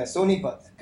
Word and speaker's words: Sonipat: Is that how to say it? Sonipat, is that right? – Yeah, Sonipat. Come on.